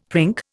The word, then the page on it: prink: Indian